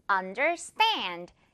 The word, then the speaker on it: understand: 자, 먼저 첫 번째 단어, understand. 이 stand 부분에 강하게 그리고 stand 이렇게 세 글자가 아니라 stand 한 글자처럼 영어식으로.